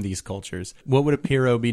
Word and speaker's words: pirogue: A Cajun canoe is called a Piro. – I'm into that. – But it's spelled P-I-R-O-G-U-E. – Yes. – I think maybe just spell it P-I-R-O-W.